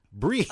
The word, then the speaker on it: BRIIZE: But it's spelled B-R-I-I-Z-E. – So it's spelled like RIIZE. Yeah, it's spelled like RIIZE, which is the name of their group, but with a B in the front. – Yeah. – That's really... And for some reason, now the pronunciation becomes an E. Breeze.